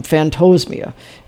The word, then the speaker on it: phantosmia: It's phantosmia. I'm pretty sure is how you pronounce it, like phantom, phantosmia.